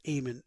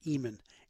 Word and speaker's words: Eamonn: He even told me how to pronounce his name, although with my Irish descent, I knew as soon as I saw E-A-M-O-N-N that it was Eamon, not Eamon. Eamon!